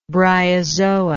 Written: bryozoa

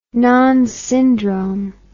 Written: Nonne's syndrome

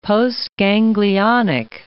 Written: postganglionic

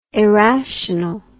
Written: arational

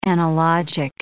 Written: analogic

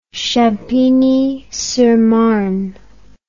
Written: Champigny-sur-Marne

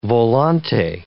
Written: volante

